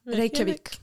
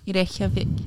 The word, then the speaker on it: Reykjavik: How do you say that? Reykjavik? – Reykjavik. – Reykjavik.